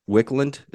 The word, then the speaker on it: Wicklund: And just for my clarity, Wicklund, is that how you say it? – Yeah, Hannah Wicklund.